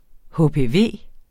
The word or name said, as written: HPV